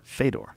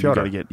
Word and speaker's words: Fyodor: Everybody else says Fedor. – Yeah, yeah. – But Fyodor. You gotta get fancy on us. Huh? – Right, like Dostoevsky. It's Fyodor Dostoevsky, not Fedor.